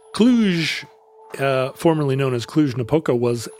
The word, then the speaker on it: Cluj: I've just been on a road trip from Berlin to Romania via Prague and Budapest all the way to Cluj, Romania. – Uh-huh. – I'm probably saying that wrong. – No, you are saying it correctly.